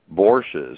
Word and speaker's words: bourses: And other countries like Iran and others, and Venezuela starting up bourses, I don't know how you pronounce that. – Bourses, yeah.